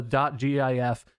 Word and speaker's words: GIF: But if the man who invented the file format and came up with the nomenclature and invented the word and invented the .gif all that shit. And he was like, it's GIF, you know, like giraffe or gin.